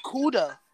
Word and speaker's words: Cuda: It's pronounced Cuda.